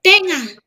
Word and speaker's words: tainga: Tainga. Tainga, tainga, tainga, or tainga, tainga, tainga. – Tainga. – Tainga. Also, these days, people say tenga. Instead of A-I, we spell it into E.